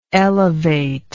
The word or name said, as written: elevatingly